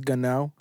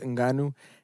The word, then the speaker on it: Ngannou: Today I was watching his come up, and it's funny how at first they didn't know how to pronounce his name, so they said Francis Ngannou. That's what Joe Rogan and everybody was calling him until they finally found out it was Francis Ngannou.